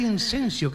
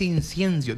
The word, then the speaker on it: incienso: Es que no se dice incenso, que se dice incienso.